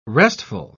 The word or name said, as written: restful